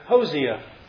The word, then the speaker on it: Hosea: The Authorized Version Pronouncing Guide, the King James, pronounces it Hosea. And I'm kind of inclined that that's probably right. The Strong's Hebrew lexicon says it's Hosea.